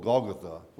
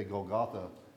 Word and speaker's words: Golgotha: There was a mountain or hillside out on the outskirts of Jerusalem called Golgotha. We often say Golgotha, but the correct pronunciation is Golgotha.